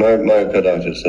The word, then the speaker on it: myocarditis: What are your concerns with, am I pronouncing it right? Myocarditis? – Mm-hmm. Myocarditis. That's right.